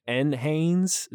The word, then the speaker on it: NHANES: And so how do you say it? Nanes? NHANES? Is that how you like to say it? – NHANES.